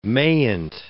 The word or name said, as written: mayn't